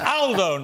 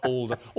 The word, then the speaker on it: Aldo: It's Aldo, not Aldo. Aldo's a very British pronunciation of an Italian name.